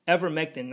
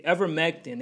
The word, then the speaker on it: Ivermectin: So yeah, people want to take this Invertamin. Ivermectin, not even saying it correctly, Ivermectin, they want to take the Ivermectin as a substitute for the vaccine.